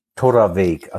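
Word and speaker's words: Torabhaig: Torraveg. Also so ein BH spricht man wie ein, wie ein V oder wie ein W.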